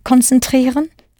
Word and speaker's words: konzentrieren: Did you remember the reflexive sich in sich konzentrieren? Hoffentlich, hopefully, I hope so. But note that in German the first C changes to a K. Konzentrieren. And the second C becomes a Z, pronounced Z. Konzentrieren.